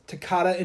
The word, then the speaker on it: Toccata: Number five is Toccata and Fugue in D minor. – Mm-hmm. Did I say that right, TT? You did.